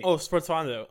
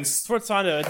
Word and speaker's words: sforzando: S-F-O-R-Z-A-N-D-O. Is like a Sportsando. – That's how you pronounce it. – Yes. – I can't even. – Sportsando.